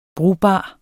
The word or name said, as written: brugbar